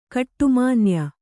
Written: ಗೌಡುಮಾನ್ಯ

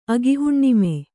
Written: ಅಗಿಹುಣ್ಣಿಮೆ

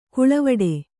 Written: ಕಾಳವಟ್ಟ

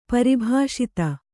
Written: ಪರಿಭಾಷಿತ

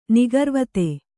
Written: ನಿಗರ್ವತೆ

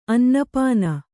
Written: ಅನ್ನಪಾನ

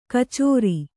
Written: ಕಛೇರಿ

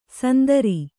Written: ಸಂದರಿ

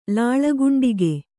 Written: ಲಾಳಗುಂಡಿಗೆ

